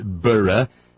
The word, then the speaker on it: burgh: You will end your love affair with the letter Z, pronounced Z, not Z, and the suffix I-Z-E will be replaced by the suffix I-S-E. You will learn that the suffix bur, B-U-R-G-H, is pronounced burra, e ergo gratias Edinburgh, lesson I had to learn recently.